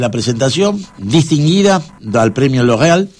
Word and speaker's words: L'Oréal: Está muy bien pronunciado L'Oréal.